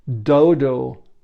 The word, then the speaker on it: Dodow: For example, there is a dodo machine that I have been using that is, I find, phenomenal. It's spelled D-O-D-O-W.